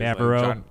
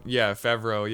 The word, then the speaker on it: Favreau: So, I think John Favreau I don't know how you say it. Favreau. – Favreau, yeah.